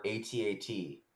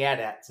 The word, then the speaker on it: AT-AT: So I learned that there's a big argument about whether it's pronounced at or A-T-A-T, the walking machines. – Yes. – And I have always said AT-AT, and I don't give a shit if it's AT-AT.